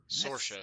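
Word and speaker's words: Saoirse: I was thinking Cerise Ronan. – Sorsha, I think it's pronounced. – Is that how you pronounce that? I'm so sorry. – I think it's actually pronounced Sorsha, but... – All right. – I may be mistaken, but I think that is the Celtic pronunciation.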